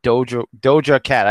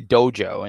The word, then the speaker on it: Doja Cat: It's Doja Cat. I always say Dojo, and I'm being corrected.